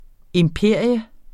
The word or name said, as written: IMPERIETS